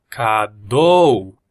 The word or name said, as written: cadou